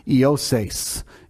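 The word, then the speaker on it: Iosais (Ἰωσῆς): And now most times we say Yosis, it's a shortened version of Joseph, but according to the Greek lexicon in the Greek, it's Iosais. Iosais. That's really technically how you say it.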